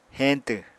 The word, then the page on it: heñtw: Apatani